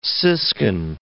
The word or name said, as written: siskin